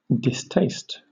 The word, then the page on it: distaste: British English